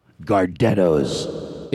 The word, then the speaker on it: Gardettos: What are they? Gardettos? Am I saying it right? Yeah. Gardettos.